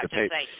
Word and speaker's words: DePape: would you say his name was DePape? Is that how you say it? – Yeah, DePape.